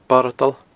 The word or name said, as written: boradal